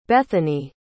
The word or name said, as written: Bethani